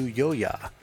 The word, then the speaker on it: Joja: Is the oddly titled I Saw You Yo-Ya, presumably a person's name. It's spelled J-O-J-A, and it seems to be about taking personal responsibility instead of blaming others for your misdeeds.